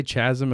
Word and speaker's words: chasm: Would you say chasm or chasm? – I would definitely say chasm if I was trolling, but chasm is how it's pronounced.